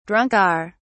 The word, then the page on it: drunker: British English